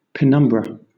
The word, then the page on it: penumbra: British English